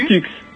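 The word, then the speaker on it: P!tux: Pitux. – Ça s'écrit. – Ça s'écrit P point d'exclamation Tux.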